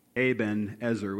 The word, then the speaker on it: Ebenezer: And so the contraction of the two words gives us the name Ebenezer, which is actually the way it should be pronounced.